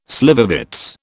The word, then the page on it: slivovitz: British English